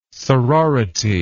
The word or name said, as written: sorority